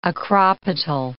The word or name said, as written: acropetal